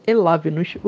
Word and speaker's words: Elavnos: Gostaria que vocês nos seguissem em nossas redes sociais, então por favor procure por Elavnos. O V é mudo. E se escreve assim, E-L-A-V-N-O-S, parece uma palavra russa ou um feitiço, na dúvida, não pronuncie em voz alta, tá ok?